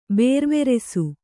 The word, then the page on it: ಬೇರ್ವೆರೆಸು: Kannada